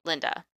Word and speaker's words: Lynda: I keep on wanting to call her Lydia because it's spelled L-Y-N-D-A, but it's Linda.